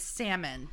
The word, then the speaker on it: salmon: It is salmon.